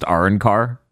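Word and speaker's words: Arrancar: I don't know how the original dub says it, but in the U.S. dub, it's pronounced Arankar, just so that way we don't get through the whole episode without me doubting.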